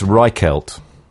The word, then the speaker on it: Reichelt: It's Reichelt.